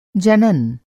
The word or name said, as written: जनन